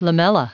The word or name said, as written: lamella